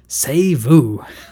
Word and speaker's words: C'est vous: And then for all the Americans who don't know how to pronounce C'est vous, they print it a third time, S-A-Y-V-O-O, C'est vous.